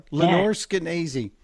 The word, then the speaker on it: Lenore Scanese: She goes, if you're saying it like crazy, yes, you are. Lenore Scanese.